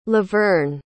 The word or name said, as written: levern